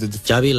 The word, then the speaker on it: Xavi: So I heard Xavi like chocolate. – Yes. Okay. Thank you very much. – Xavi